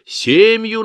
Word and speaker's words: семью: Он любил повторять: «Семью надо делать, Лариса», причем с ударением именно на «е» — «семью».